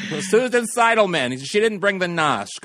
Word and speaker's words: Seidelman: Directed by Susan Seidelman. Did I pronounce that right? – Susan Seidelman.